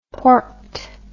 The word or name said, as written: porked